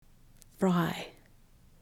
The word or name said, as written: fry